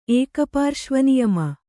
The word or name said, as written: ಏಕಪಾರ್ಶ್ವನಿಯಮ